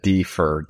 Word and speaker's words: DFIR: That's it, DFIR, digital forensics and incident response.